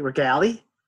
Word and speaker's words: Regale: You don't pronounce the E. Regale?